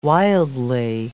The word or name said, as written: wildly